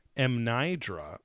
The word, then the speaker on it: Mnajdra: It is home to the megalithic structure known as Mnaidra. And it's spelled M-N-A-J-D-R-A.